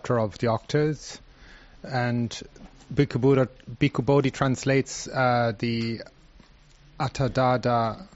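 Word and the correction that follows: Attadaṇḍa: How do you say it? Atadada Sutta. – Atadanda. – Atadanda Sutta.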